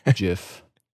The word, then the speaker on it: GIF: I use a soft G. GIF.